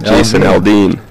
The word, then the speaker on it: Jason Aldean: Jason McLean? No. – Aldean. Jason Aldean.